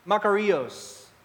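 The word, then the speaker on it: makarios (μακάριος): Blessed, the Greek for blessed in the Bible is pronounced makarios.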